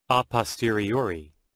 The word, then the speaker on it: a posteriori: A posteriori. – Okay, we all get to say that together. Let me do it again. Some of us are whispering it. That's good. – A posteriori.